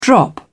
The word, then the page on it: drop back: British English